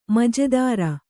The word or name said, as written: ಮಜೆದಾರ